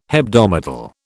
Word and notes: hebdomadal: British English

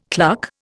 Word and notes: cluck: Indian

